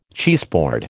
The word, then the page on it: cheeseboard: American English